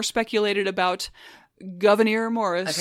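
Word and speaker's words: Gouverneur: Again, his name is derived from his mother's Huguenot French Protestant name, surname, pronounced Governor.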